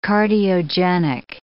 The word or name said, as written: cardiogenic